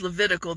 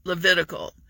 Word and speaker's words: Levitical: Critical rhymes with Levitical. That's how you can tell the law in modern day Christianity. Critical, Levitical.